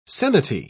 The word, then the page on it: seniti: British English